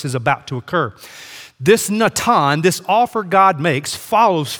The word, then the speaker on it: natan: That phrase set before is the Hebrew word natan. So for my note takers, write this in your margin, N-A-T-A-N. Natan is how you pronounce that.